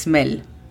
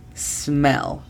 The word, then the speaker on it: smell: La pronunciación fonética de smell es S-M-E-L. De nuevo, es muy importante que la pronunciación de la S y la L sean correctas. Escucha la diferencia. Es smell. Así lo decimos, smell.